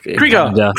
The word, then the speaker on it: Krugar: Krugar is like Krugar. Krugar, yes.